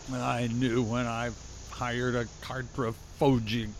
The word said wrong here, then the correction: cartographer: Cartographer. If you can't pronounce it, Grimble, mapper will do.